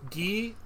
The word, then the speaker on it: gi: I've ever known is it pronounced gi or g? – Gi. – Gi. Yeah.